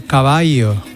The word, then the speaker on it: caballo: Qué bonito es escuchar de vez en cuando caballo. – Caballo, que, ¿no? Que es como realmente se pronunciaba la L.